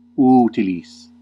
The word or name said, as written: fit